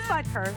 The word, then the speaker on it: Butker: It is Butker. I said it right.